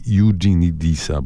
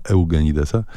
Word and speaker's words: Eugenidesa: I to oni są głównymi bohaterami powieści Jeffrey Eugenidesa, bo to tak się wymawia, choć e, można też z polska Eugenidesa.